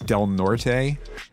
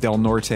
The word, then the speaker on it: Del Norte: Del Norte? It's spelled D-E-L-N-O-R-T-E.